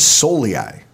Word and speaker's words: Soliai: Similarly to him, you know, Paul Sol, uh, I think it's Soliai. That's how I've always pronounced it.